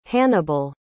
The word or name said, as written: hannibal